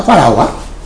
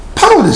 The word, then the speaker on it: ファラオ: ファラオは何て私たち発音したんですか。パロですよね、パロ。でも発音的にはやっぱファラオの方が一般的なんですね。これ教科書なんかでもファラオで出てきます。その方がやっぱりいいのではないかということで、そういうふうにしてファラオ、